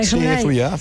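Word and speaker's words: Zuia: Corrígeme si lo pronuncio mal, porque lo pronunciaré mal seguro. Cuando ya estás cansado de nosotros o del mundo en general, te vas a Zuya y juegas con los chavales que siguen jugando por allí, o te refugias ahí, ¿o no? – Sí. Es, ¿Lo he pronunciado bien donde... o lo he pronunciado mal? – Zuya, sí, es el municipio donde vivo, donde viven mis aitas, mis padres, y donde me he criado.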